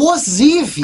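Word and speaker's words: Ozivy: Eu tava com dúvida também como pronunciava, então eu fui procurar. É o Ozivi.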